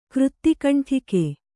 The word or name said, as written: ಕೃತ್ತಿಕಂಠಿಕೆ